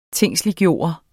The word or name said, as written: tingsliggjort